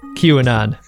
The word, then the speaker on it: QAnon: uh, they uh, I've got to I've got to express some gratitude for the uh, pronunciation key here, uh, advising me that the pronunciation of QAnon is in fact QAnon.